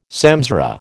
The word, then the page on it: samsara: American English